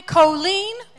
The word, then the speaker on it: Colleen: And I'm going to move on to Miss Allie Colleen. – Allie. – That is Colleen, not Colleen. It rhymes with Jolene, just so you know. Keep that in your head.